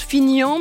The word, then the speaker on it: Finhan: Attention à cette commune, elle nous tend un piège de prononciation. Ça se prononce Fignan, mais ça s'écrit F-I-N-H-A-N.